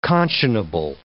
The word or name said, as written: conscionable